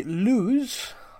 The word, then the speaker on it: Lewes: They play Lewes. I think it's pronounced that. L-E-W-E-S. Lewes? – Yeah, Lewes, I presume. Yeah, yeah, I think it's Lewes.